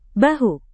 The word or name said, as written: bahu